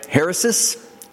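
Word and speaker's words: heresis (αἵρεσις): However, it went on to state that the Greek word heresis, I'm going to pronounce it that way, heresis, H-A-I-R-E-S-I-S, from which heresy is derived, was originally a neutral term that signified merely the holding of a particular set of philosophical opinions.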